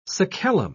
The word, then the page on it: sacellum: British English